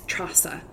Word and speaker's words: Treasa: If I go to Ireland, in Gaelic we've got a very hard T. – Yeah. – So my name's Trasa. So they've been able to pronounce it properly.